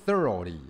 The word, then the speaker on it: thoroughly: 底下这个单词叫 thoroughly、哦、各位刚刚有跟各位介绍一个 GH 都没有发音的、哦、就是所以你会听你没有听到 GH 就是 O 的声音、哦、 thoroughly、哦、仔细地。